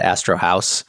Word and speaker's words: Astro House: So I call it Astro House. I'm not German, so I'm just going to say it in an anglicized way.